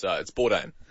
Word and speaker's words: Bourdain: Yeah, it's Bourdain. – Yeah. Okay, well, it's Bourdain Day, Mike, which honors the legacy of Anthony Bourdain, the iconic chef.